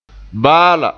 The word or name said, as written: baa la